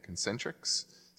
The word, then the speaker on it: Concentrix: Yeah, Concentrix.